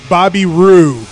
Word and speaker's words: Bobby Roode: Well, one, you got to say his name right. It's Bobby Roode.